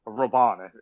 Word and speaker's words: Robben: Robben, actually, is how it's pronounced.